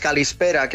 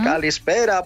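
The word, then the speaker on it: calispera (καλησπέρα): Mira, cuando te digo buenas tardes, calispera, qué sonido más bonito, y es súper fácil de decir, calispera para caló.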